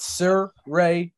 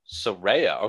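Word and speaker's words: Saraya: We've all been pronouncing it wrong. – It's Sir Ray. Yeah. Yeah. – Yeah, no, it's Soraya. – Soraya.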